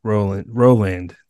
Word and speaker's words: Rowland: Today, we are joined by Rebecca Rowland.